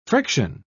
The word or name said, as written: friction